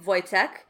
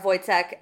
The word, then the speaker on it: Wojtek: Spelled with a W, pronounced with a V, because... – Well, it's Polish. – many of the lower officers take care of Wojtek and...